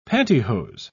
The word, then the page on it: pantihose: British English